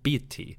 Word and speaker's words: Bata: His name was probably pronounced Biti, and he was the lord of the town called Saka in southern Egypt.